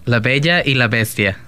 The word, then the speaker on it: La Bella y la Bestia: La Bella y la Bestia.